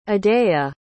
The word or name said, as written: adaya